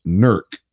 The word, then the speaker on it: Newark: But Gort earlier said that Newark, Ohio is pronounced Nurk. Is that true? – That is Nurk, true.